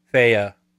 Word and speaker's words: Fe: And it's actually, I found out it's actually pronounced Fea.